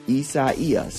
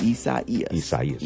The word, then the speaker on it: Isaias: You said Isaias. – So I thought you said psoriasis or psoriasis. – Isaias. – Oh. – Yeah, that's how you pronounce it.